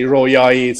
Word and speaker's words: Roayayi: We try to say Royayi to make it easier for everyone.